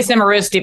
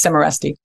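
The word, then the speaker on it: Cimarusti: I said it wrong for the first 10 years I knew him. I used to say Cimarruzzi, but it's Cimarruzzi.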